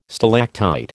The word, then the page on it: stalactite: American English